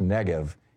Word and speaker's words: Negev: He said, the thing I would try to do if I was Israel right now is I would just bulldoze something I think it's pronounced Negev, N-E-G-E-V.